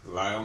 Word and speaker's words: Lyle: Lyle McFadden, L-Y-L-E, M-C, capital F-A-D-D-E-N.